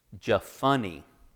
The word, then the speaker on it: Jephunneh: Jephunneh. Caleb's dad had a very funny name. Isn't that so funny? Is that, is that right? PH is F.